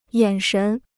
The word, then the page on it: 眼神: yǎn shén